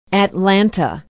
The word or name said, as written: atlanta